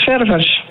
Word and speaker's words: Ververs: Hoe spreek je jouw achternaam precies uit? – Dat spreek je uit als Ververs. – Ververs gewoon? – Ja. – Oh, ik dacht dat het misschien wel een vrij chique naam, Frans, Ververs. – Nee, daar kom ik niet vandaan.